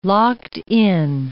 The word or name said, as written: locked-in